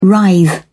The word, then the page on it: writhe: British English